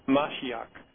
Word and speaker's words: Mashiach: You know, we talk about His anointed, Mashiach, I believe is the pronunciation, but Messiah, the Hebrew word for Messiah, and His anointed, as it's found in Psalm 2.